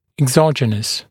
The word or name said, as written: exogenous